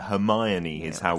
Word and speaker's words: Hermione: Yeah, Hermione is how we pronounce that in British English.